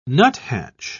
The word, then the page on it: nuthatch: British English